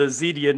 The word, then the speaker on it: Zedia: We are part of the Zedia Network. That's media spelled with a Z.